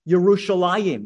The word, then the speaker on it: Yerushalayim: And when you read the Hebrew, it's pronounced Yerushalayim with a yus sound.